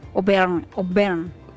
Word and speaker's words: Auvergne: Auvergne. – En francés, ¿no? Es Auvergne.